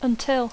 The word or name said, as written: until